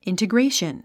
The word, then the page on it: integration: ìntəɡréiʃən; インテグレェイション